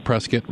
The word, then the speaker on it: Prescott: We're talking to aviation psychology expert Dr. Aaron Bowen from Embry-Riddle Aeronautical University in Prescott, rhymes with biscuit.